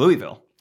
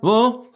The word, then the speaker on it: Louisville: You know, because I always used to think it was Louisville. – Louisville Slugger. Yeah. – Of course. But yeah. – It turns out, no. – No, no, no. – And so I don't know what the joke would necessarily be. But like, you know, I was talking to some of you guys. And from what I can understand, like, the less actual sounds I make, the closer I am to pronouncing it.